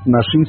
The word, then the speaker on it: Naxin: Nashin se escribe Naxin. Y es el mazateco, que significa venadito.